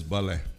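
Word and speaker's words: ballet: Se escreve ballette, mas se lê em francês balé.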